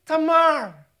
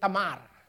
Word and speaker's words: Tamar: You see, Tamar or Tamar, whatever your pronunciation. In Filipino, we pronounce it Tamar.